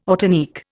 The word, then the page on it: ortanique: Indian